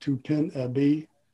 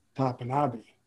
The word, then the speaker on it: Topinabee: Most people pronounce it Tupinabee, T-O-P-I-N-A-B-E-E. Well, I asked one of my language instructors how he would pronounce it, and he said it would probably be Tapanabe.